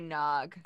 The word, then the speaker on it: Gnog: G-N-O-G. I don't know. Would you pronounce it Gnog? – Is the G silent? – I would say Nog.